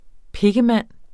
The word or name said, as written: pikkemand